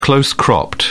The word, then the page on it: close cropped: British English